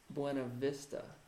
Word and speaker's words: Buena Vista: It's pronounced Buena Vista. – I know. – Shut up. – They don't pronounce it correctly in their own town.